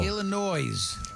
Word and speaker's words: Illinois: Illinois. – That's how you say it.